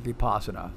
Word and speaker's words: Vipassana: I've also heard it called, you know, like pronounced Vipassana, but I checked it online and found out that it's called Vipassana, is what I found.